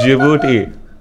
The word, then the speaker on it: Djibouti: It's not a Djibouti, bro. It's Djibouti. – Djibouti. – The D is silent.